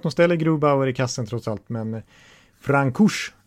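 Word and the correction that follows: Francouz: han, eller Franchos tror jag man ska. Man ska uttala det som en fransos helt enkelt.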